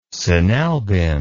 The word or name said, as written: sinalbin